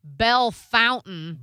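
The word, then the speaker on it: Bellefontaine: Oh, in Ohio, they had Belle Fountain. – Belle Fountain. – Except it was spelled like lovely. It was spelled Bella Fontaine. But ooh, it was Belle Fountain.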